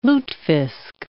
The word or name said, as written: lutefisk